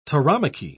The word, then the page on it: tauromachy: British English